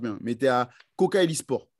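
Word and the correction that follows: Kocaelispor: Alors, c'est Koja Elisport.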